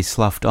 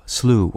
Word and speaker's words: slough: There are two words spelled S-L-O-U-G-H in English, as well as two nouns, and both sets have different pronunciations. The first noun, referring to a swamp or a discouraged state of mind, is pronounced to rhyme with either blue or cow, slough or slough. Its related verb, which can mean to plod through mud, has the same pronunciation. The second noun, pronounced to rhyme with cuff, slough, refers to the shed skin of a snake, as well as anything else that has been cast off.